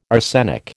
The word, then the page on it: arsenic: American English